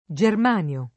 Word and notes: germanio: lingua italiana

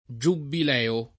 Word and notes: giubbileo: giubileo; lingua italiana

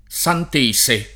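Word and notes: santese: italiano

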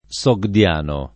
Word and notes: sogdiano: lingua italiana

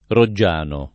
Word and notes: Roggiano: italiana

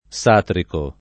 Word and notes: Satrico: lingua italiana

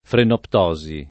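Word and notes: frenoptosi: italiano

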